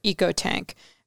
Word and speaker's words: EcoTank: Clark, for years I have heard your on-air struggle with the word for the Epson printer, EcoTank. May I settle it for you once and for all? It's a long E, so think economy, ecology, eco-friendly, or e-commerce, Wes.